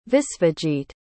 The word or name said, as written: visvajit